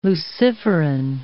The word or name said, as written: luciferin